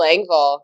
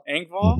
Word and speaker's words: Engvall: Oh, Bill Engvall. – Engvall.